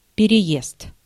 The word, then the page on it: journey: [ˈdʒɜː(ɹ)ni]; /ˈdʒɝni/; /ˈdʒɜːni/